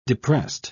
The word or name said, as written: depressed